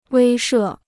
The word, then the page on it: 威慑: wēi shè